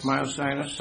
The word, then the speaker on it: myositis: and how do you pronounce that word? – Myositis? – Myositis. – Something like that.